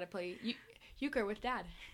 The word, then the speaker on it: euchre: Dad wants to teach us euchre. Is that how you say it? – Yeah, I'd like to learn euchre.